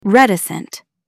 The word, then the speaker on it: reticent: Stress the first syllable, reticent. Say it aloud, reticent.